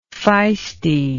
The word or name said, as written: feistiness